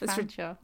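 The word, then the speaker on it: Featherstonehaugh: Or like Fanshawe, the world's most irritating surname, which is spelt Featherstone Hall. And it's not pronounced that. It's pronounced Fanshawe.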